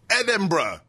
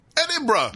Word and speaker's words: Edinburgh: He said it right. Edinburgh. What is it with American brothers saying Edinburgh? Edinburgh. – Nope. Edinburgh.